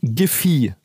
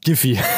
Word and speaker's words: Giphy: Die, ich habe die gerade die Seite, die Wikipedia-Artikel von der Seite aufgemacht, die explizit Giffy sagt. – Ah, von der Aussprache her. – Danke. – Wenn das, wenn das Produkt in dem Sinne ein GIF, ein GIF etc. ist, ist mir relativ egal. Die Firma heißt Giffy.